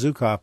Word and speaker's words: Zuckoff: And his last name is spelled, by the way, it's pronounced Zukov, but it's spelled Z-U-C-K-O-F-F.